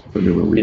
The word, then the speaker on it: Enuma Elish: And the Enuma Elish, which we're going to talk about. Did I say that correctly? – Yes, Enuma Elish.